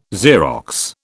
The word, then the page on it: Xerox: British English